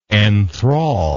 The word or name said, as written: enthralling